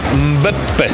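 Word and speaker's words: Mbappé: Karola, ja to rozumiem i pozdrawiam kibiców razem z tobą i ciebie też pozdrawiam, ale w tym miejscu trzeba przypomnieć o poprawnej wymowie tego nazwiska. – Mekkambę, jak on? – Mbeppe.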